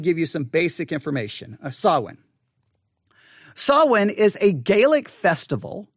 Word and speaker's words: Samhain: Again, it's spelled S-A-M-H-A-I-N, but it's Samhain.